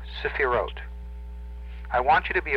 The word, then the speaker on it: Sephiroth: S-E-P-H-I-R-O-T-H. Now, the T-H is pronounced as a hard T. And the first E is a very short, very quick E. It's a. It's not long and drawn out. It's a very quick one. Sephirot. And the accent is on the last syllable.